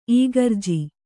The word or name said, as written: ಈಗರ್ಜಿ